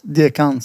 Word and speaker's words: Dekan: Och jag brukar bara säga att man, man uttalar det som Stefan. Alltså det är inte så svårt. Dekan, Stefan.